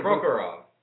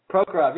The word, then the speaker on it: Prokhorov: We'll go to the pro, Mikhail Progokhov. Did I say that right? You're the Prokhorov. – Prokhorov.